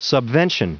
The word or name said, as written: subvention